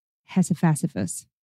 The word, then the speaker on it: Hephaestus: Anyway, Hephaestus. It's the Greek pronunciation.